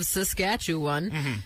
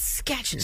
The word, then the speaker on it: Saskatchewan: He seems to take the four syllables of Saskatchewan and turns it into two and calls it Sketchin'.